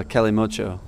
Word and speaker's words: Kalimotxo: How do I pronounce that? – It's a Kalimotxo.